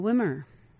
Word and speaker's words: Wimmer: And from Maria Wimmer, rhymes with swimmer.